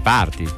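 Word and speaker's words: Partaty: Sì, ma non si dice tai. Partai, si dice parti. – Parti, esatto. Forse voleva dire così, ma non c'è il trattino, niente. Vabbè. Comunque lui è par, niente trattino, poi T-A-T-Y party. Come tai, però parti.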